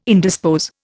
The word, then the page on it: indispose: Indian